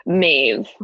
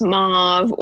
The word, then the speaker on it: Maeve: Maeve, which people pronounce as Mauve or Maud.